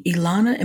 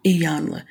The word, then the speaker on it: Iyanla: That show, one of the things that stood out to me was how Ilana, am I saying that right? – Iyanla.